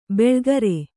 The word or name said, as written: ಬೆಳ್ಗರೆ